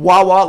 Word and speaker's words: Wawa: Wawa, like water. – Okay. W-A-W-A.